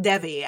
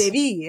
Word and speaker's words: Devi: So I received that when I was a monk, and I too pronounced it Devi. And so, but I was schooled that it's a Sanskrit name. And so the E is pronounced as an A.